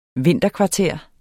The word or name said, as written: vinterkvarter